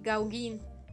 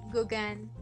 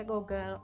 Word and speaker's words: Gauguin: siempre le decía Gauguin Y hasta que estuve con ella fue como que ella le decía como, ah, pues sí, claro, Gauguin Y fue como de, ah, güey, se pronuncia Gauguin What?